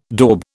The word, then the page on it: daube: British English